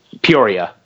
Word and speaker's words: Peoria: Is that in Peoria? Is that, am I saying that right? – Peoria.